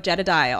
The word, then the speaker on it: Jedediah: 25% off with the code Jedediah. All you got to do is put in my name, J-E-D-E-D-I-A-H.